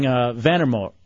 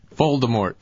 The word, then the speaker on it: Voldemort: All right, Putin says it's like saying Vandermort in Harry Potter. Is that how you say it? – Voldemort.